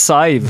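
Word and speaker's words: Sadhbh: Och jag kommer inte ihåg om det här är 100% rätt, men jag tror att hennes stavning på irländska är S-A-B-D-H. – Sibbidej. – Och det uttalas Saiv.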